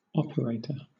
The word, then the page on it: operator: British English